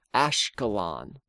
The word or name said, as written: Ashkelon